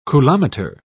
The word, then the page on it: coulometer: British English